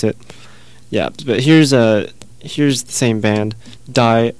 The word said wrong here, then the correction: die: D-I-E is pronounced D in Dutch, and I keep pronouncing it Die because it looks like Die.